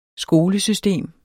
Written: skolesystem

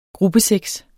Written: gruppesex

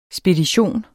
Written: spedition

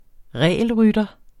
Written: regelrytter

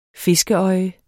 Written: fiskeøje

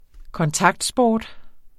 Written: kontaktsport

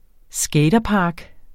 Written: skaterpark

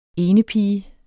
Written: enepige

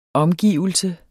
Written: omgivelse

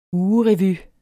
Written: ugerevy

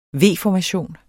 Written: V-formation

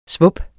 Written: svup

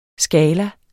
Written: skala

